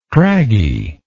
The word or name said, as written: craggily